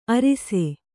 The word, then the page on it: ಅರಿಸೆ: arise